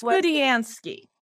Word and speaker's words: Budiansky: And let's see, we got, and this is one we were talking about, Bob Budiansky, if I'm saying that right. – I think it's Budiansky.